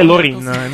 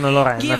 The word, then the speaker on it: Loreen: La pronuncia corretta è Loreen, non Lorraine, come contemporaneamente ho detto.